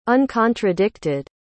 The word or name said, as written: uncontradicted